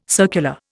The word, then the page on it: circular: Indian